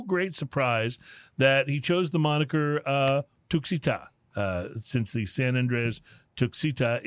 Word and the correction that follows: Tuxtla: T-U-X? I'm sorry, I mispronounced it. It's Tuxtla.